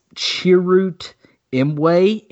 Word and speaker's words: Chirrut Imwe: but it's Chirrut Imwe in Rogue One, walking across the beach at the end of the movie.